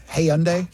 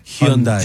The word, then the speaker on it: Hyundai: How do you say it? Hyundai? – Hyundai.